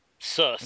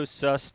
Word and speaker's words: Cius: And now I just got notice today we will be on the Cisco Sius tablet, because they are, I have no idea, C-I-U-S. How do you pronounce that? C-I-U-S. – C-I-U-S. – Exactly. – Sus. – Okay, it's a Cisco Sus tablet.